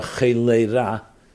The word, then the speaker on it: cholera: Cholera, cholera. In English, pronounced cholera, C-H-O-L-E-R-A.